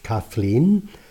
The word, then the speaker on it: Caitlin: When he and his Irish wife Caitlin, whose name by the way can also be pronounced Kathleen, started a family, the first two children were given Welsh first names.